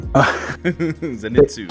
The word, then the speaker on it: Zenitsu: But like Inosuke and Zenitsu, I'm still saying it wrong. – Zenitsu.